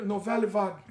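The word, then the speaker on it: Nouvelle Vague: Fala aí, porque é francês melhor. Novelle, novelle vague. Novelle vague. É assim? Falei certo? É.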